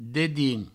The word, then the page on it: dedins: Provençal